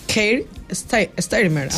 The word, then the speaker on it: Keir Starmer: Keir Starmer, sí.